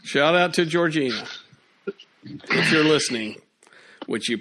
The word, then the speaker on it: Georgina: No, don't get me started on Georgiana. – I am. It's Georgina.